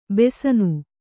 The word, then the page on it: ಬಾಸಣ: bāsaṇa